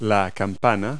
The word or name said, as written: bell